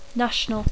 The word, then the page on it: national: English